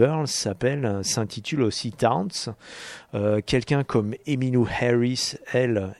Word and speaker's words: Townes: Alors Towns, ça s'écrit T o e hein, c'est Townes. Hein. Towns.